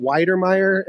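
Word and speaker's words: Wydermyer: Obviously, some of the big ones are, Trey McBride, Jalen Weidermeyer, is that how you say his name? – Yeah.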